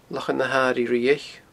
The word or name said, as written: Lochan na h-Airighe Riabhaich